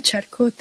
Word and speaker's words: Charcot: Charcot, dilo como hablando en español, Charcot.